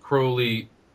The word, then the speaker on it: Crowley: Uh, Crowley, by the way, is pronounced Crowley, rhyming with holy. That's the way he rhymed it in uh, one of his poems called The Beetle.